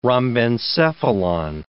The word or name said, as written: rhombencephalon